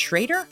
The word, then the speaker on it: Schroeder: It's written Schroeder, but it's pronounced Schrader? – It is, yeah. Okay. And Schrader, I think, sounds a lot more tough. – like Shredder. – Yes.